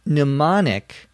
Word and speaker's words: mnemonic: She says, I used a mnemonic to remember those last two digits. A mnemonic, M-N-E-M-O-N-I-C. Notice the first letter, M, is silent. You do not pronounce it.